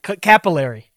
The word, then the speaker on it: capillary: Calipari action. I cannot pass that. – Capillary. – Capillary. That's it. I pronounced the middle part of the word strong instead of capillary action.